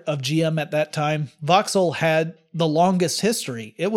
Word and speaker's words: Vauxhall: Sloan was head of GM when the company acquired an English company called Vauxhall in 1925. That's spelled V-A-U-X-H-A-L-L.